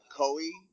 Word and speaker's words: Koi: Koi. Kind of like Koi, you know, the Koi fish.